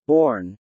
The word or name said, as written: borne